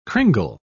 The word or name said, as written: cringle